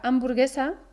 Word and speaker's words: hamburguesa: Curiosamente, en la palabra hamburguesa, que también es una palabra extranjera, la H es silenciosa y no se pronuncia.